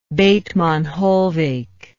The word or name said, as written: Bethmann Hollweg